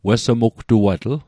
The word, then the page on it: wessmugtuatl: Mi'gmaq